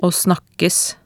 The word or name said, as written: å snakkes